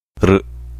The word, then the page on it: R: vowel